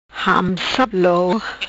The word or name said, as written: Hamsuplou